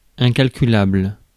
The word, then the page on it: incalculable: France; [ɛ̃.kal.ky.labl]